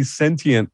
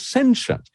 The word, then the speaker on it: sentient: Uh, well, interesting thing for me actually is given that you grew up in Britain, that you say sentient the American way, which is, is three syllables with a hard T in it. The British way, if you listen to Patrick Stewart, and uh, the way it was originally conceived as a word is two syllables, like quotient doesn't have a hard T in it, nor does sentient.